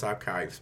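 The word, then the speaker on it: ArQuives: Which is still pronounced Archives, but it's spelled with a Q.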